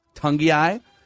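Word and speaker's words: Togiai: This interior defensive lineman from Ohio State named Tommy, is it Tugai? – Tungiai.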